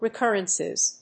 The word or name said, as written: recurrences